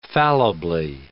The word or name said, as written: fallibly